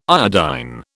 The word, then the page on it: iodine: British English